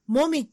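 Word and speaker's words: 紅絹: 口紅の紅に、絹、うん、シルクの絹と書いて、もみって読ませるんですかそれはすごいわ。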